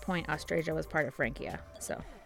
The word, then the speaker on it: Francia: It's Francia, hard C.